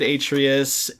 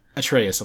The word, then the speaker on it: Atreus: He's raising this kid, Atreus, and like... Atreus, I'm sorry. I'm pronouncing it the way it's spelled. Atreus. – Put some respect on it. – I'm sorry. Atreus.